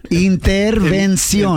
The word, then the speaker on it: intervención: Yeah, it's the N. Just get the N, Prima Vani. Intervención.